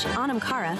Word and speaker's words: Onamkara: In Irish, Onamkara, pronounced Onamkara, means soul friend.